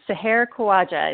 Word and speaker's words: Seher Khawaja: Pretty much. It's Seher Khawaja. It's it's very close.